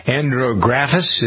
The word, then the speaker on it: Andrographis: Andrographis, is that right? – Yes.